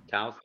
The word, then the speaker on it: Taos: Taos like mouse.